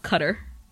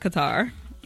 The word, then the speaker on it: Qatar: Nikki said it's pronounced cutter. – Okay, thanks, Qatar. Nikki. – Thanks. It's pronounced cutter, not Qatar. It looks like Qatar to me, but she said it's cutter.